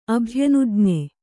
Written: ಅಭ್ಯನುಜ್ಞೆ

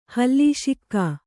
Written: ಹಲಸಿಗ

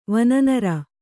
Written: ವನನರ

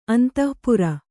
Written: ಅಂತಃಪುರ

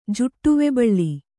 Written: ಜುಟ್ಟುವೆಬಳ್ಳಿ

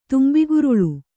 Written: ತುಂಬಿಗುರುಳೆ

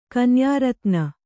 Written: ಕನ್ಯಾರತ್ನ

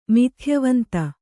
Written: ಮಿಥ್ಯವಂತ

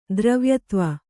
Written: ದ್ರವ್ಯತ್ವ

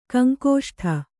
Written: ಕಂಕುಷ್ಠ

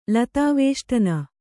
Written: ಲತಾವೇಷ್ಟನ

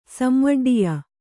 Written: ಸಂವಡ್ಡಿಯ

